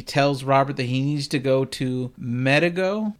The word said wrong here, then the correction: Megiddo: Megiddo. – Megiddo, an old city in Jezreel, and see a man named Bunnenhagen.